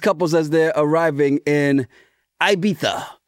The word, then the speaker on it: Ibiza: Spanish pronunciation is Ibiza, Ibiza. But anglicized, the British pronunciation is Ibiza. But Americans will say Ibiza.